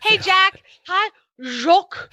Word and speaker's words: Jacques: And now you can't say the name without using a French accent. Jacques. And apparently last week I pronounced it right.